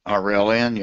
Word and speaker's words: Aurelian: Next question after you comes from KD, who says, years ago, company called, I'll see if I can pronounce this right, Aurelian Resources. Does that sound right? – Yep, Aurelian, yep.